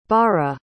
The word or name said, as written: barra